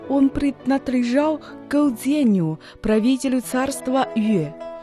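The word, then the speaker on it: 越: Юэ произносится в четвертом тоне.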